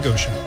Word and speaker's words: Goshen: Am I saying that right? Goshen? – Mm-hmm, yeah. – The land of Goshen.